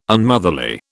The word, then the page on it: Unmotherly: British English